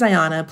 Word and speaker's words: Ayana: And actually, now I'm probably, I try not to be mean about it, but I'm pretty firm about no, my name is Ayana.